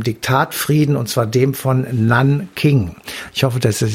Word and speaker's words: Nanking: Also man kann es nachgucken, NAN und dann King, also Nanking.